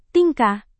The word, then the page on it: tingkah: Indonesian